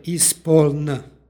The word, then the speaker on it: ispoln: stihu izgovorili smo ispoln zemlja. Ovaj oblik ispoln jeste pridev, ali nepromenljivi pridev, dakle indeklinabilni. I ovdje je izgovorno n na kraju, dakle ispoln.